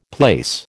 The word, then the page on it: place: American English